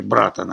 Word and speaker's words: братан: Правда, с ударением на первом слоге – «братан», они говорят.